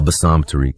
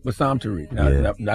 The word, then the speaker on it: Bassam Tariq: Bassam Tariq. – Bassam Tariq.